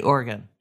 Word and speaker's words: Oregon: Notice how I say it correctly, Oregon.